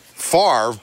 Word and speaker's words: Favre: K-I-L-N. – Now, and your name, too, is pronounced Favre, but it's spelled... – F-A-V-R-E.